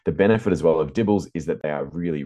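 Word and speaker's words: DIBELS: Well, I'd say Dibbles because that's how they pronounce it.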